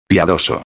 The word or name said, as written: piadoso